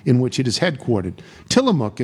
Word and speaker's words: Tillamook: The mook part is pronounced like cook. Tillamook. I said Tillamook.